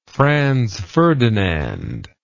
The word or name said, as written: Franz Ferdinand